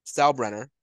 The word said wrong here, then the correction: Steinbrenner: why can't I fucking pronounce his last name? Hal Steinbrenner.